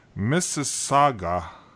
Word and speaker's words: Mississauga: So, I got notification that it was shipped, and it was shipped from Mississauga, Ontario.